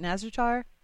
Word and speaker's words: Nazjatar: And I know we're going to take kind of a diversion in 8.2, heading to a new zone and checking out is it Nazjatar? Is that how you pronounce it? – Yeah, I think that's how it's pronounced, yeah.